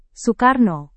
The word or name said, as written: soekarno